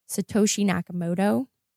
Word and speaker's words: Satoshi Nakamoto: And the first one is going to be Satoshi Nakamoto.